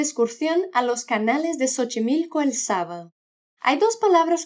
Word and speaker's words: Xochimilco: Y otra palabra es Xochimilco, que empieza por X. Cuando esto pasa, esta letra suena como S. – Xochimilco.